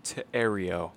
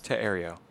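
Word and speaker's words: Thériault: it's Teario. Teario. That's how it's pronounced.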